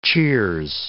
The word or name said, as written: cheers